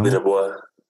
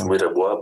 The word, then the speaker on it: Mwirebua: Mwirebua. Yes, it's Mwirebua, but Mwire is a short form.